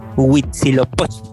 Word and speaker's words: Huitzilopochtli: Huitzilopochtli.